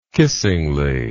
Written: kissingly

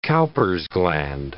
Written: Cowper's gland

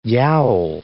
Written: yowl

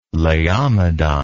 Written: laomedon

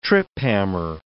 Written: trip-hammer

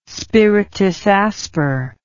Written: spiritus asper

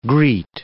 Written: greet

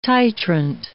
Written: titrant

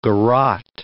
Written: garrote

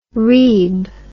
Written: reeb

